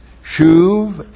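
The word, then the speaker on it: shuv (שוב): Well, we have in this passage on repentance, biblical repentance here is taught with two verbs, shuv and nacham. Shuv, S-H, long U-B. And also the verb nacham.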